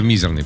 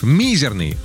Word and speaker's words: мизерный: Значит, вы же сказали, что «мизерный», правильно, да, на первый слог? – Ну, да. – Однако вариант с ударением на второй слог считается классическим и строго литературным, но он есть. То бишь, «мизерный», он допустим в нашей речи, но если вы в какой-то момент решили блеснуть безупречной грамотностью, выбирайте вариант «мизерный», либо «мизерный».